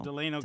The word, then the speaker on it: Delano: Is it Delano or Delano? – Delano, California.